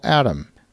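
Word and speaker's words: ATTOM: There is a real estate data company called ATOM. That's spelled like it sounds, but with two T's, A-T-T-O-M.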